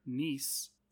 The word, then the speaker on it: Nice: The movie was filmed in or near Nice, France. I think that's how you pronounce it, right? It's not Nice. It's spelled like English Nice, but I think it's Nice.